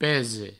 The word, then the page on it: pese: Provençal